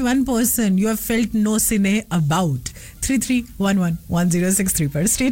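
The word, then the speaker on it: gnossienne: is called नोसिने जी द स्पेलिंग इज जी एन ओ एस एस आई ई एन एन ई नोसिने yes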